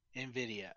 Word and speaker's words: NVIDIA: So, you know, there was the NVIDIA, that's how it's said, right? – NVIDIA.